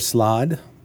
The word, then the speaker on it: slaad: And today, we're going to talk about the chaotic side of things with the slod. That is how you pronounce it, right? S-L-A-A-D? – Singular slod, plural slotty.